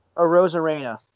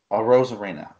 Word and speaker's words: Arozarena: How do you pronounce his last name? – Arozarena. – Arozarena.